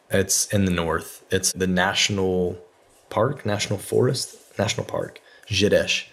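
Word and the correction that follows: Gerês: Okay. – It's Gerez. – You guys have good Portuguese pronunciation, so props for that.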